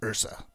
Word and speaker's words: URSA: And that's spelled with the URSA, but it's pronounced URSA.